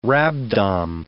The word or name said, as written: rhabdom